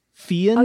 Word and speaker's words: Fearn: F-E-A-R-N. Fearn? – Oh, yeah. Fearn. – Fearn? – No, I heard you correctly. I wasn't sure if it was Burn with a B.